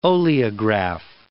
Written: oleograph

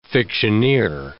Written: fictioneer

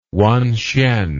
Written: wanhsien

